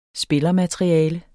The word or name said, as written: spillermateriale